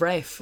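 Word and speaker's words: Ralph: And so she's up in Rafe Fiennes of the fancy pronunciation of Rafe.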